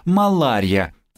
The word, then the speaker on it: малярия: «Хороший воздух, по-итальянски «буонария», противоположение дурному воздуху, малярия. Отсюда происходит слово «малярия», которое обыкновенно произносится с неправильным ударением на «и».